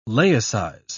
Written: laicize

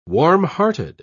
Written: warmhearted

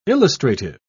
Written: illustrative

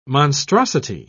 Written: monstrosity

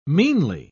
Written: meanly

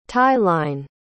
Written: tie-line

